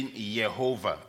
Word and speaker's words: Jehovah: The word Lord, here it is the word Jehovah, or you can say Yehovah, because they don't pronounce the word J.